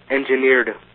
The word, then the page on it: engineered: American English